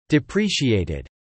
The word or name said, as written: depreciated